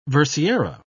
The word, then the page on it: versiera: British English